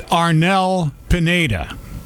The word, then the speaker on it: Arnel Pineda: Is it Ernell Pendenna? – You're pretty close. – I don't really know how to pronounce him. – Yeah, he's from the Philippines. It's Arnel Pineda.